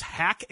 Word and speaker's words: Haack: The Browns have signed punter Matt, I think it's pronounced Hack, H-A-A-C-K.